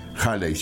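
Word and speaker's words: Hailey: Es una novela de Arthur Holley. Halley se pronuncia.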